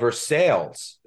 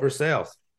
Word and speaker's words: Versailles: There's a town called Versailles. It's not Versailles. It's spelled like Versailles, but they pronounce it Versailles. I come down here. I'm like, Versailles? They're like, no, it's Versailles.